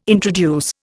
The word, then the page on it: introduce: Indian